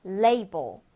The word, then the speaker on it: label: 라벨이 아니고요, 여러분. label. label로 읽어줍니다.